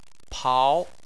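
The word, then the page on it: pao: tone 2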